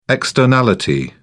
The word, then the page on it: externality: British English